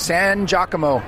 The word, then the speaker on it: San Giacomo: Am I saying that right? – Yep, San Giacomo.